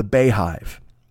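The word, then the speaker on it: Beyhive: So I guess the Beehive, I guess, but it's spelled B-E-Y hive. So I'll say the Beehive because it's Beyonce, but I thought it was the Beehive because of the way it's spelled.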